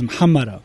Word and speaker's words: Muhammara: The real way to pronounce it is Muhammara.